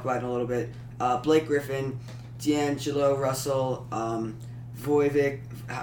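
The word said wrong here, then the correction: Vučević: Vucevic, Kyle Lowry, and the special player is Dirk.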